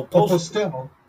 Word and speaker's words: Postel: No, John Postel. He said it right. – Oh, Postel. Postel invented the .com.